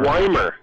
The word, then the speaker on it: Ymir: October 4th at the Old Schoolhouse in Emer, Y-M-I-R. – Oh, Weimer. Sorry, I didn't even know what you were saying. Yeah, Weimer, it's spelled, I heard it before I saw it spelled, and I thought it would be spelled like W-E-I-M-E-R, but it's, I think, yeah, Weimer, like Y-M-I-R, which is, I believe that's like a Nordic...